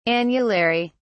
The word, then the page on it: annulary: British English